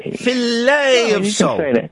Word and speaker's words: Filet of Soul: Do you say Fillet of Soul or Filet of Soul? – Filet of Soul. Hey, you can say it any way you want.